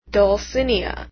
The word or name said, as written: dulcinea